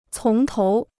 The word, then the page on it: 从头: cóng tóu